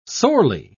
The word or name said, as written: sorely